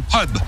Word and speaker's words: pub: On dit pub. En anglais, le U se prononce E.